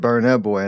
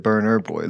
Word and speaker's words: Burna Boy: Oh, you know, and I even said it wrong. You said it right. You said Burna Boy, and I said Burner Boy.